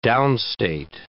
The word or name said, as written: downstate